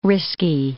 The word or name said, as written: risky